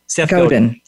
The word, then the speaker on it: Godin: Seth Godin.